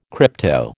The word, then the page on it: crypto: American English